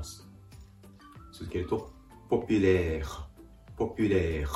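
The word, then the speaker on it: populaire: ポピュレー、ポピュレー。 R の発音で単語が終わっています。やや発音が分かりづらいかもしれませんが、ふをいうような形で、息をここで止める。発音を止めるような形で、ポピュレー。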